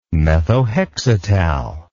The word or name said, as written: methohexital